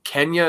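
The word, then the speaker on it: Quenya: How do you say? Is it Kenya? Is that the language? Yeah.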